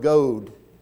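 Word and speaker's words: gōd: One was the word goad, long O, spelled the same way as God, but it was goad, and it meant good. And then the word spell, S-P-E-L, was an Old English word which meant news or story.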